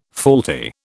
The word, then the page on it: faulty: British English